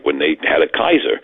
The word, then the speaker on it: Caesar: So we pronounce it Caesar, but in classical Latin, the C was a hard C, it was a K. So it was Kaiser.